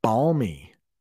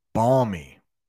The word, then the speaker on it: balmy: The next one is balmy. I'll pronounce that again. The next one is balmy. Balmy. That L is barely pronounced. Balmy. I don't even know if I pronounce the L.